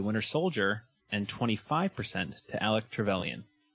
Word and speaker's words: Alec Trevelyan: Our previous episode was The Winter Soldier versus Alec Trevelyan.